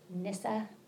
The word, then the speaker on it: Nyssa: And the second is a poem by St. Gregory of Nyssa.